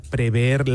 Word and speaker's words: prever: A mí no me consta que hubiera ningún informe que pudiera prever la magnitud de lo que ocurrió. – Prever, cuidado. – Muy bien pronunciado, prever.